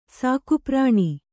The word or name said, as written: ಸಾಕುಪ್ರಾಣಿ